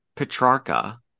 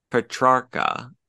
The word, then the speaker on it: Petrarca: And directed by David Petrarca. Petrarca. P-E-T-R-A-R-C-A.